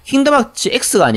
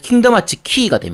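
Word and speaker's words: 킹덤 하츠 키가 (Kingdom Hearts χ): 근데 킹덤 하츠 X가 아니고요. 그게 약간 이렇게 삐뚤하게 그려져 있는 X라서 키라고 발음합니다. 그게. 그래서 킹덤 하츠 키가 됩니다.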